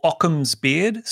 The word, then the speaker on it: Ockham's Beard: If you search for Occam's Beard, spelt with a K, so O-C-K-H-A-M-S-B-E-A-R-D, you'll find my Twitter account under that name.